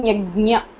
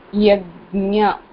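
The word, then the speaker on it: yajna (यज्ञ): And the second one is gnya. Gnya. As in yajna. Gnya. Technically, yajna.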